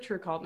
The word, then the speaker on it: TRVEKVLT: Everyone pronounces it True Cult now, so.